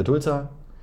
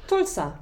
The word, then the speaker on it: Tulsa: für Tulsa trainiert, überlegt, oh Gott, wir müssen doch nochmal klären, wie es ausgesprochen wird. – Tulsa.